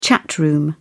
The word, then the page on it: chat room: British English